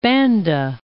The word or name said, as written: Banda Islands